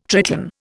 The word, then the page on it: Triton: Indian